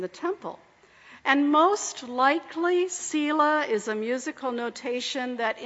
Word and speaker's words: Selah: Now, we're not real sure how it's pronounced. Some say Selah, Selah, Selah, Selah. The Hebrew people back in the day knew how it was pronounced, but most scholars today agree that it's Selah.